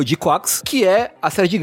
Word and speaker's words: GQuuuX: É assim que se pronuncia? – É G-Quax. É G-Q-U-U-U-U. Eu não sei quantos U são. U-U-U-U-X. – Acho que no anime eles falam de Quax mesmo.